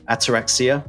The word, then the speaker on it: Ataraxia: And then I think it was Dennis had just put out an EP, I can't remember when it was, called Ataraxia.